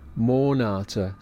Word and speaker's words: Monate: Monate, stress on Mon.